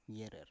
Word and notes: yerer: Biak

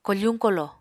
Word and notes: kofuñ kolo: Apatani

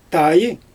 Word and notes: taj: Xyzyl

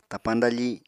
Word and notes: tapañ dafi: Apatani

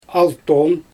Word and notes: alton: Xyzyl

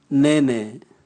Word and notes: nene: Raga

